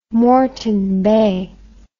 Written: moreton bay

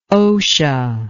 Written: OSHA

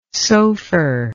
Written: sofer

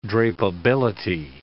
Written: drapeability

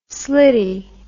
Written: slitty

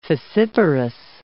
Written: fissiparous

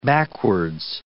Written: backwards

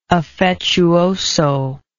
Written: affettuoso

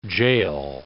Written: jail

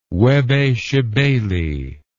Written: Webbe Shebeli

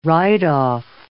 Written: write-off

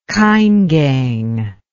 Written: Kaingang

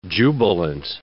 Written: jubilant

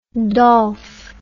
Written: dolf

